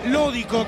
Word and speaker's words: Lódico: Este para Lódico, con acento en la primera O.